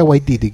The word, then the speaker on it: Waititi: Nunca, nunca lo voy a pronunciar bien. Waika Tetiki. – Eh, Tetiti, taika, taika, Waititi. Taika Waititi, que es el de What.